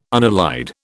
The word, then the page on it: unallied: British English